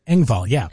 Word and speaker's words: Engvall: Man, Brandy says, Bill Engvall. Did I say that right? – Engvall, yeah.